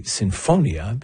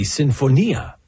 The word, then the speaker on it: sinfonia: So the author of this letter said that as an Italian speaker, he found it grating when he heard me say sinfonia, because in Italian that word, which is an Italian word, would be sinfonia. Now, in this case, this composer, Mariana Martinez, lived in Vienna. So there it would be pronounced sinfonia, or something closer to that.